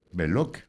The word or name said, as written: Loque